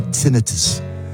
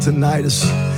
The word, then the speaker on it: tinnitus: I have a ringing in my ears, and my doctor pronounces it tinnitus, not tinnitus.